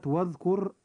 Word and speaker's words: واذكر: وكلمة واذكر اسم ربك، كلمة واذكر، الراء في كلمة واذكر جاءت مكسورة فيجب أن ترقق أيضا عند النطق.